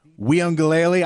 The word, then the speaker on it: Uiagalelei: DJ Uiagalelei.